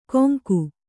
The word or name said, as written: ಕೊಂಗು